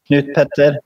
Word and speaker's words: Knut Petter: But a lot of people, they want to try to pronounce my name and they're not used to like the Norwegian way of doing it, where you can have like two first names, because my name is Knut Petter.